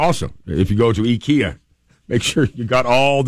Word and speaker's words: IKEA: They're saying you don't pronounce it IKEA, you pronounce it IKEA. IKEA. That's the Swedish way to pronounce it.